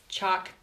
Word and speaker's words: Teach: Okay, so as some people might know, House of Brigid, its Irish name is Chalk Breed, which is spelled T-E-A-C-H, and that's Irish for house, correct? – But why is it a funny title? – Because chalk sounds like talk.